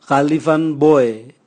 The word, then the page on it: galivanboe: Raga